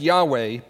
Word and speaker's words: Yahweh: Scripture uses the term YHWH, sometimes pronounced Yahweh, before this, but we join with Moses in hearing the divine name first proclaimed in Exodus 3.